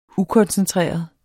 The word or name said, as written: ukoncentreret